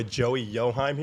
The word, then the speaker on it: Yochheim: We got our boy Joey Yochheim here.